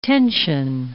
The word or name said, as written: tension